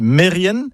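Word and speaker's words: merien: Et puis, un autre mot, par contre, qui lui n'est jamais bien prononcé, c'est Mérienne. Mérienne, comme ça ne vous parle pas. Mais si je dis Mérien, bah oui, vous allez reconnaître le nom de famille, Kermérien, le nom de lieu. Eh ben non, tout ça, c'est faux. C'est Mérienne, Kermérienne.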